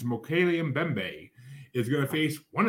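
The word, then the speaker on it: Mokele-mbembe: Mokele Mbembe.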